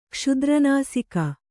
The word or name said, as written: ಕ್ಷುದ್ರನಾಸಿಕ